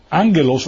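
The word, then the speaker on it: ángelos (ἄγγελος): Ángelos, la palabra ángelos es ángel, se escribe con una doble G, escribe en el griego, y esto se pronuncia A-N, ángelo.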